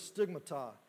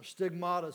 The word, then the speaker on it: stigmata: The marks that he talks about, the word is stigmata, or stigmata, some pronounce it.